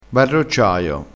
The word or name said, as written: barrocciaio